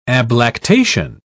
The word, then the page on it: ablactation: British English